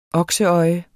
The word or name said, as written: okseøje